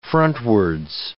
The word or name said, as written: frontwards